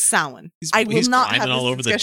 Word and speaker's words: Samhain: It is pronounced Samhain. Okay, it's spelled Samhain.